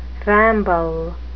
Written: ramble

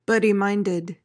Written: bloody-minded

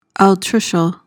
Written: altricial

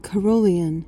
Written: carrollian

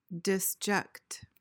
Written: disject

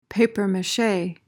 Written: papier-mache

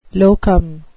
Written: locum

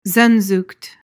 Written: sehnsucht

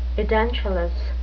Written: edentulous

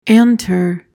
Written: antre

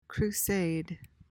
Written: crusade

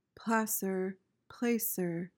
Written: placer

